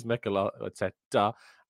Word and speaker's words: Mikel Arteta: But it is Mikel Arteta and Serie A.